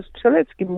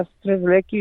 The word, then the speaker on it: Strzelecki: Na Strzeleki już jest tak się po prostu utarło i dlatego takie zakończenie było, że ja niby śpiewam o Strzeleki, ale w końcu gdzieś z tłumu, no, sama tam siebie nagrywałam, z tłumu ktoś krzyczy, no Strzelecki ma być i tyle.